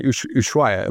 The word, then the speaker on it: Ushuaia: Real quick on Ushuaia, I'm saying this right? – Yes.